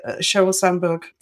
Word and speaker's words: Sheryl Sandberg: Going back to that message that Sheryl Sandberg, did I say her name right? – Yes.